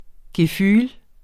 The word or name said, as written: gefühl